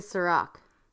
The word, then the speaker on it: Sa-Roc: Always put the emphasis on sa, because that's how it's spelled. – I always say sa-roc.